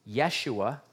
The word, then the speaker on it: Yeshua: This is why, by the way, if you ever listen to Messianic Jews talking, they refer to Jesus as Yeshua. That would be his Hebrew pronunciation.